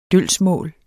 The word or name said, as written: dølgsmål